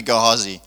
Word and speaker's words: Gehazi: It's going to be Gehazi, which works well because then it, huh? No, I also prefer Gehazi. Because then it rhymes with be free and see.